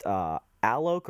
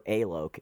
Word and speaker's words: Alok: I think it's pronounced Alok or Alok. It's A-L-O-K.